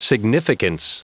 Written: significance

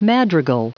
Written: madrigal

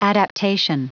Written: adaptation

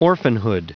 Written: orphanhood

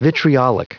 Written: vitriolic

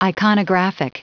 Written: iconographic

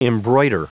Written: embroider

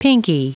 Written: pinkie

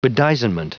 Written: bedizenment

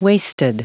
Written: waisted

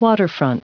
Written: waterfront

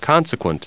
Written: consequent

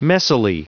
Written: messily

